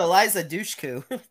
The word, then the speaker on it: Eliza Dushku: El Elsa Dushku? I don't know. Soul Survivor's wrong turn. – Oh, you're talking about Eliza Dushku. – Yeah, Dushku, that is.